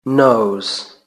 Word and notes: nose: British English

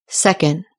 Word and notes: second: British English

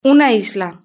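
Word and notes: una isla: Spanish (Spain)

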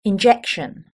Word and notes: injection: British English

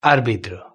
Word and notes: arbitro: Spain Spanish

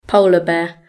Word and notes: polar bear: British English

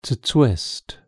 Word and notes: twist: British English

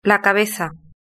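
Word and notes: la cabeza: Spanish (Spain)

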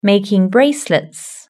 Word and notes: making bracelets: British English

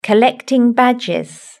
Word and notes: collecting badges: British English

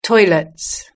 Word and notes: toilets: British English

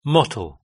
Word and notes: mottle: British English

